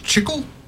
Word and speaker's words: chicle: Did you know, Chiclets, do you know where they get their name from? – No. – I guess it's pronounced Chicle. Chicle.